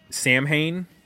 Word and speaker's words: Samhain: I think it's Samhain.